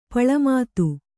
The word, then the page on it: ಪುಳ್ಳೆಮಾತು: kannada